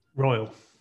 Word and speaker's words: Royle: Royle.